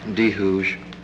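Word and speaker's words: D'Hooge: My name is John DeHooge, and it's, the last name is spelled capital D apostrophe capital H O O G E.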